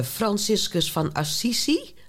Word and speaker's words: Franciscus van Assisi: En dat is een eerbetoon aan de heilige Franciscus van Assisi.